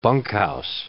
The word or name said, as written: bunkhouse